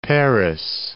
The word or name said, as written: Parris Island